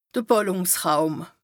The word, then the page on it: de Bàllùngsräum: Bas-Rhin; Strasbourg